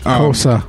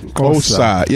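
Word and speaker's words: Xhosa: He pronounces like Kosa. Yeah, like the click goes in the beginning. – It's all clicks. That X that we see, you don't hear.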